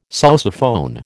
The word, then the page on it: sousaphone: American English